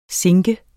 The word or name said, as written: sinke